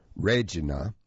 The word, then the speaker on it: Regina: After tourism officials in Regina, Canada, pronounced Regina, apologized for sexualizing the city with two new slogans, "Show us your Regina," and "Regina, the city that rhymes with fun."